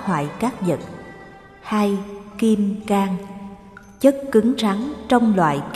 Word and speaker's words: Cang: Kim Cang, chữ Cang người Nam đọc là Cang, người Bắc đọc là Cương, có hai nghĩa.